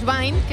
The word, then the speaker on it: Vind: es Pauls Vind, que se escribe Pauls y V-I-N-D, Vind.